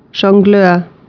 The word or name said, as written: Jongleur